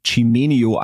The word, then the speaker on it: Cimenio: Ähm, wer in Online-Shop-Kontexten sich über Bewertungen interessiert, der sollte sich auf jeden Fall das Unternehmen Chimenio anschauen. Ich glaube, es wird so ausgesprochen, also C-I-M-E-N-I-O Com.